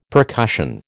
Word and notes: percussion: American English

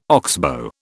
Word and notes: oxbow: British English

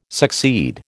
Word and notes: succeed: American English

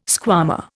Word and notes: squama: Indian

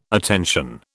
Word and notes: attention: British English

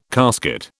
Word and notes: casket: British English

